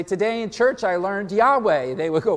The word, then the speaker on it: Yahweh: When the vowels of Adonai were combined with the consonants of Y-H-W-H, you get Yahweh. Which is likely, which is the widely accepted among Bible scholars as the closest approximation of the original pronunciation of his name, Yahweh.